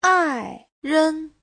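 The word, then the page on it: 爱人: ai4ren5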